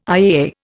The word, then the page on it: IAA: Indian